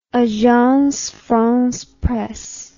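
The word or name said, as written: Agence France Presse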